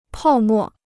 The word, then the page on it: 泡沫: pào mò